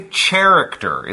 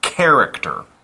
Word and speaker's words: character: And that's spelled C-H-A-R-A-C-T-E-R, C-H-A-R-A-C-T-E-R. Careful though, don't say character. It's not a C-H like in the word chair, it's、 mm-hmm. – a K sound, character.